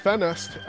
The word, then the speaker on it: Ffenest: It's a single from a group called Ffenest, F-F-E-N-E-S-T.